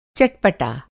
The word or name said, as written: चटपटा